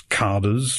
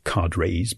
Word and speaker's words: cadres: And the local officials, referred to as carders, sometimes pronounced as cadres by Americans, the local carders have to whip up the workforce in one drive after the other.